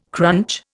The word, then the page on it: crunch: Indian